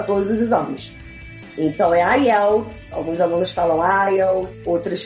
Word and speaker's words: IELTS: Tem aluno que chama de ILETS. – ILETS. mas o correto, a pronúncia correta é IELTS, né? Então o, o, o stress da, da palavra está no E e TOEFL.